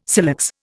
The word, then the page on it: silex: Indian